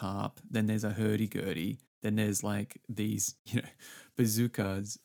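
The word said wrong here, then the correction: bouzoukis: Bazookies. – Bazookies, not bazookas.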